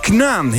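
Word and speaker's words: K'naan: Hoe moest je dat ook alweer uitspreken? Kanaan? – Knaan, heel goed.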